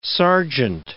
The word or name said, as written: sergeant